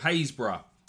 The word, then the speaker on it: Happisburgh: is the village of Haysburgh, spelt Happysburg, H-A-P-P-I-S-B-U-R-G-H, but definitely not pronounced that way.